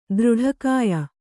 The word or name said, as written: ದೃಢಕಾಯ